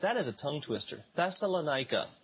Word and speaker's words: Thessalonica: But in Thessalonica, I think I said it right that time, you've got this delegation of unbelieving Jews who stir up trouble in the city.